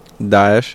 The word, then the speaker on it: Daesh: We did, by destabilizing the entire region, that planted the seeds for groups like Daesh. Did I say it right? – Daesh, yeah.